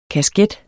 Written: kasket

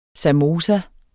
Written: samosa

